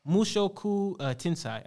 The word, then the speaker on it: Mushoku Tensei: Mushiku Tensei. It's Mushoku Tensei. I think that's how you pronounce it.